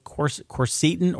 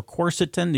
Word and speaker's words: quercetin: But I do believe in an herb called quercetin or quercetin. You can pronounce it either way.